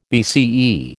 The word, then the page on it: BCE: American English